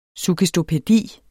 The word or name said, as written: suggestopædi